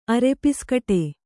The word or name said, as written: ಅರೆಪಿಸ್ಕಟೆ